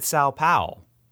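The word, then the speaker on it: São Paulo: Now you might be thinking, I think you said it right, Sao Paulo. Sao Paulo. – Well, he didn't say the O. He said Sao Paulo.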